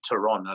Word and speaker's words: Theron: Theron, as you probably pronounce it there. But actually, the actual surname there is actually Theron. I don't know if you know that or not. But that's actually the South African way of pronouncing it.